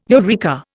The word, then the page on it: eureka: Indian